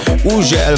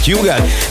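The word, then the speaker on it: Hugel: Így van az úriember, akinek minden országban másképp ejtik a nevét. Van, ahol Uzsel, van, ahol Hügel, van, ahol Ugel, meg mindenféleképp, de nálunk úgy mondjuk, hogy Hügel, és Salto és a Jamaican Bam Bam érkezik, a csavarjátok fel a hangerőt.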